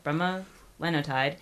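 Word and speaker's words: bremelanotide: It's pronounced, help me with this, bromelanotide. There we go, bromelanotide.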